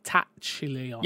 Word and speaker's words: Tachyon: The Tachyon Anomaly event will, I hope I'm saying that right, Tachyon. – Yeah, you are.